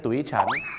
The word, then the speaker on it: tuition: tuition โอ้ tuition เลยนะฮะโทษนะครับเขาออกเสียงว่า Tuition นั่นเองครับ Tuition นั่นเองครับ Tuition นะครับไม่ใช่ tuition หรือ tuition